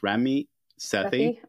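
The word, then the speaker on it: Ramit Sethi: And for those people who don't know who you're referencing, I believe his name is pronounced Rami Sethi.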